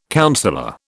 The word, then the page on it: councillor: British English